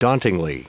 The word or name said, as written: dauntingly